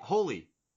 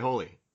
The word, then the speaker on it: Hole: Harry Hole? – Holy. – I'm sorry, it was Harry Hole in the movie. – I've always pronounced it Harry Holy. But it's Norwegian, too, so it's not...